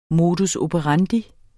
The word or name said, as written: modus